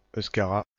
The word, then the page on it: euskara: /euʃ.ka.ʁa/